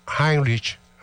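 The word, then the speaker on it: Heimlich: Lá, e escrever, por isso que eu estou dizendo como é que escreve é, em H-E-I-M, em Lich, L-I-C-H. Tá? Então, Heinrich, Heinrich, a pronúncia.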